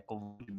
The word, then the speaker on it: Kobold: Detalhe, é Kobold mesmo. Eu sei que essa é a pronúncia por causa dos audiobooks.